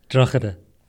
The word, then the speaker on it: Drogheda: Drogheda. – So many K's. – I wanted to correct Dara on his own pronunciation of it, but I felt like I should just let it go. – From Drogheda.